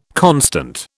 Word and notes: constant: British English

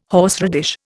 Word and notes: horseradish: Indian

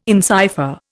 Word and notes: encipher: Indian

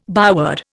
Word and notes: byword: Indian

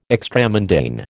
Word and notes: extramundane: American English

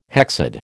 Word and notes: hexad: American English

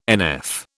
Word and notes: NF: British English

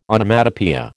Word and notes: Onomatopoeia: American English

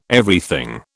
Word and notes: Everything: British English

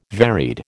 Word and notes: varied: American English

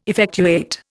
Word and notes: effectuate: Indian

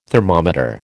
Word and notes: thermometer: American English

